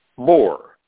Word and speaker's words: Boer: Bore, the Dutch spell it B-O-E-R. The Anglos and the Saxons just say B-O-R. A bore, the old Germanic word for farmer, boar. In South Africa, the Boers fought the English about 120 years ago, B-O-E-R.